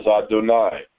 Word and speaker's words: Adonai: The reader is told to pronounce the first as Adonai, which is the usual manner to pronounce it.